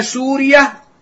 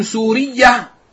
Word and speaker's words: سوريا: نزيل أنطاكية بتخفيف الياء، أنطاكية تقع في لواء الإسكندرون بين سوريا وتركيا، وسوريا بضم السين وتخفيف الياء وبالتاء المربوطة، هكذا ضبطها العلماء في معاجم اللغة وكتب البلدان، فمن ينطقها اليوم سورية خطأ، الصواب بتخفيف الياء.